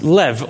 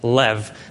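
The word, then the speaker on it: lev (לב): The Hebrew word in the Old Testament for heart is a word which is pronounced lev, or if you've studied Hebrew, you'll know that there's an accent in there somewhere. But for our sake this morning, we'll just say lev, the Hebrew word in the Old Testament, lev, the heart.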